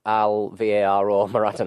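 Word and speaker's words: Álvaro Morata: You actually pronounce his name Alvaro Morata now.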